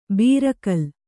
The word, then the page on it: ಬೀರಕಲ್: bīra kal